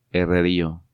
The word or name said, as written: Herrerillo